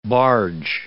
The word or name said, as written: barge